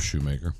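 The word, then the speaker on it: Schumaker: Is it Shoemaker? Is that the correct way to say it? Making sure I'm saying his name right. Shoemaker? – We'll go with Shoemaker.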